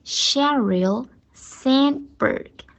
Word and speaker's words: Sheryl Sandberg: 我的女神的名字叫 Sheryl Sandberg。好。 a sh 发好了以后，r l 我们正好复习一下那个 l 的发音，舌头卷上去舔在上牙齿的后面。 Sheryl，Sheryl，Sandberg，Sand 把 n 发好，然后的不要发出来。